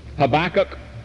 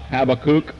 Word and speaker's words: Habakkuk: Habakkuk, H-A-B-A-K-K-U-K, that's not Habakkuk, as some of the rest of you have called it. That's the Episcopalian pronunciation. The correct pronunciation is Habakkuk.